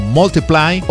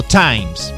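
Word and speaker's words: ×: Thinking Out Loud de Ed Sheeran está incluida en su segundo álbum X, es una X, o se pronuncia normalmente en el idioma inglés como Multiply o como Times.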